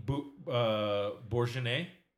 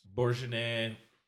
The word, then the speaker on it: Bourgogne: Bourgogne. – And then what's the solid? – It's other pronounced stuff? Pretentiousness. – Bourgogne.